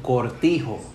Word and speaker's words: Cortijo: los gringos no podían pronunciar mi apellido Cortijo. C-O-R-T-I-J-O.